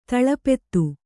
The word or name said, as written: ದಳಪತಿ